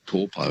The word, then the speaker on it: Taupo: Now it's called Taupo. – Taupo, yeah, that's right. It's just the vowel pronunciations are a bit different to, they don't really have that ow sound, like cow, meow.